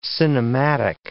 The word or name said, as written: cinematic